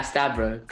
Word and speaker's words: Stavrou: Yeah, Stavrou, close.